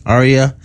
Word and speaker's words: Arya: Arya.